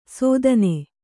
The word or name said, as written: ಸೋಧನೆ